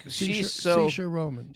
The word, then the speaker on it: Saoirse Ronan: I don't ever know how to pronounce her name, so I'm glad you, but she's like, Saoirse Ronan.